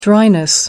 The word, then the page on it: dryness: British English